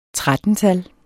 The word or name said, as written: 13-tal